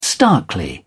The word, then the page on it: starkly: British English